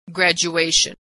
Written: graduation

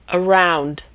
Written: around

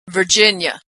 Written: Virginia

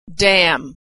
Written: damn

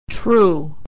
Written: true

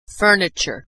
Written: furniture

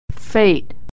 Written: fate

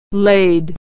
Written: laid

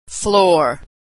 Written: floor

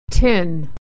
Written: tin

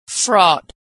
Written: fraught

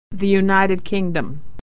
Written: the uk

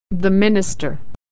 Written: minister

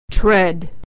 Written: tread

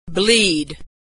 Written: bleed

